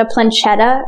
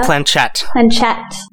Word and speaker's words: planchette: It's like a planchetta or something. Planchetta. – Planchette.